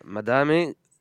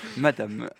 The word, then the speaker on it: Madame: avec notamment une collab avec la rappeuse italienne Madame, dont Timothée avait déjà... – Du coup, tu le prononces beaucoup mieux, parce que j'avais dit Madame. – Madame, à la française.